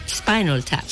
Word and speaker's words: Spinal Tap: Y mm, como digo yo, dices Spinal Tap. Así es como se pronuncia esto, como es... Eh, a ver. – Spinal Tap.